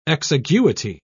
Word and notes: exiguity: British English

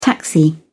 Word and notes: taxi: British English

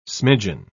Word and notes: smidgeon: British English

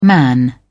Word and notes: man: British English

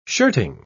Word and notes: shirting: British English

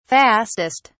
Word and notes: fastest: British English

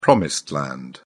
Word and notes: promised land: British English

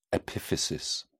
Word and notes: epiphysis: British English

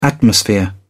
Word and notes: atmosphere: British English

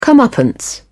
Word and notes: comeuppance: British English

